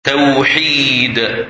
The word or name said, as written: Tawhid